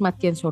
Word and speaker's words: Matienzo: Pueden buscarme en, en mi perfil de LinkedIn o en mi página de Facebook como Denise Matienzo Rubio.